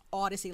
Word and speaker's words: Audacy: It's Audacy, like audio, okay? Even though it sounds like the word Odyssey, okay?